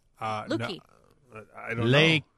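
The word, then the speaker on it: Lékué: Leaky? Leaky? – I said Luque. – Luque. – How do you spell it? I'll be the judge. – L-E-K-U-E, but it has the little like...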